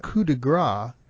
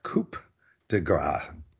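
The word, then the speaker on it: coup de grâce: Because a coup de grace is completely different from a coup de grace. – Yeah, people often spell it coup de grace. Gras is fat. – Coup de grace, yeah, excuse me. – And a coup is a cup. And a coup, C-O-U-P, is a blow.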